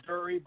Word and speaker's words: Durre: I'm not totally sure myself. I say Durre, but I kind of like the way you just said it, animal.